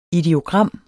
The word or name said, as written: ideogram